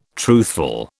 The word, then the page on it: truthful: British English